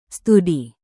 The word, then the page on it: studi: Indonesian